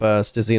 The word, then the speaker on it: Stizzy: The French pronunciation is Stiz, but we just call him Stizzy because we're English. – Yeah. – And we don't do French pronunciations.